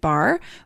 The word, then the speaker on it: barre: There are lots of yoga workouts to do, but I'm most excited about the Extend Bar with Andrea Rogers. Okay, so I've always wanted to try a bar workout. It's spelled B-A-R-R-E, if you're wondering.